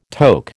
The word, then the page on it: toque: American English